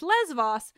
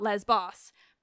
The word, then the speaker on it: Lesvos: Also, apologies for nitpicking, but the correct pronunciation is Lesvos, not Lesbos.